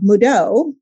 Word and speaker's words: Mudo: We are speaking with Brianna Arps of Mudo. If you didn't know, that's the pronunciation.